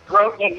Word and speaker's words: Grothman: So it basically sounds like your name is Grothman and with a lisp. – Right. Is that correct? – Grothman, yeah, like Boatman.